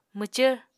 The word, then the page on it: mwcvr: Apatani